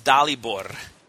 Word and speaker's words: Dalibor: It's actually pronounced Dalibor. You have to roll the R.